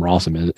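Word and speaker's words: Rossum: Like, that's why you don't kick it to a man whose name rhymes with awesome or some shit like that. So I have a soft spot for Allen Rawsome.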